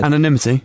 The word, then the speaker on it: anonymity: A change in the UK law in 2005 removing a I can never pronounce this word. – Anonymity. – Go on.